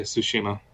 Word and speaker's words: Tsushima: We are talking about Ghost of, is it pronounced Tsushima? – Yeah, Tsushima.